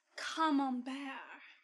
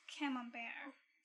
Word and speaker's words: Camembert: In fact, it's pronounced Camembert, not Camembert.